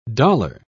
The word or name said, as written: dollar